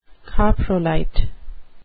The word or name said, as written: coprolite